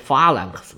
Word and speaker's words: phalanx: 亚历山大横扫亚洲的这个马其顿方阵，我在希腊史里面呢读作 phalanx，其实不对，在希腊语里面的那个 x 后边不发音，读作 phalan 就对了。